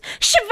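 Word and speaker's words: Siobhan: Et que le prénom Shioban se prononce Shiva C'était Luna No le retour que plus personne n'attendait.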